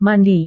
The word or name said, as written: mandi